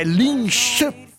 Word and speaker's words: Linköping: ci sta ascoltando da un luogo che ci scrive la pronuncia, è Linköping. Ping.